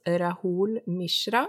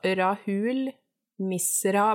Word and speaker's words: Rahul Mishra: Uh, yep, yep, Men min innspo er Jeg tror det uttales Rahul Mishra, altså Rahul Misra med SH, understrek okay.